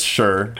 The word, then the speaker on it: Schreur: It's Schreur. – I'm not familiar with Schreur. – S-C-H-R-E-U-R.